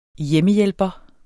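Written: HJEMMEHJÆLPERE

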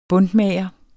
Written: buntmager

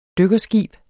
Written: dykkerskib

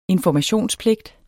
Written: informationspligt

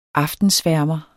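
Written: AFTENSVÆRMERE

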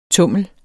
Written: TUMLENS

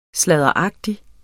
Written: sladderagtig

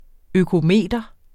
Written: økometer

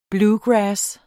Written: bluegrass

